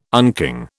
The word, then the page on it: unking: British English